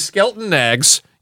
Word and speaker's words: Skelton Knaggs: This is from Anthony Birdman Pissarro, and he says, he sent me a picture, Gilbert, of Skelton Nags with a cockatoo. And he writes, and I think he's had a cockatoo in his life. – Oh my god, there is Skelton Nags.